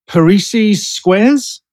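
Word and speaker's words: Parrises squares: In Star Trek, The Next Generation, there was at least one episode with a game called Parisi's Squares. Am I pronouncing correct? – It? – Yes.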